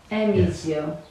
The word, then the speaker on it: Erbizio: So, I use the Z as an S when I pronounce it. – Yes, the Z can be used as an S. A strong S. – Erbizio.